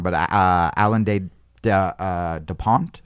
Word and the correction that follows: de Botton: or de Botton. There it is. Mm-hmm. Alain de Botton.